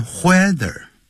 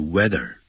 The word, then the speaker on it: whether: 那我们就在前面加一个 whether，这个不要念的 whether，要念的 weather。 H 是不发音的。